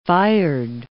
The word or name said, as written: fired